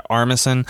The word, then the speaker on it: Armisen: Armisen, Uncle Fester.